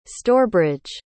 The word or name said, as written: stourbridge